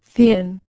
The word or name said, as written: 100